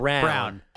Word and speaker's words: Braun: We know how it's spelled. For some reason, he says it Brown.